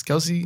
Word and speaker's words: Kelce: Uh, Travis Kelce. Kelce.